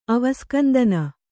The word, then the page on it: ಅವಸ್ಕಂದನ: Kannada